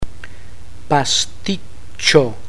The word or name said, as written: pasticcio